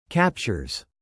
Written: captures

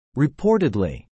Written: reportedly

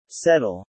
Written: settle

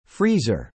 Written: freezer

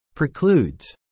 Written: precludes

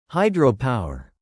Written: hydropower

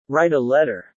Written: write a letter

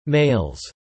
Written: males

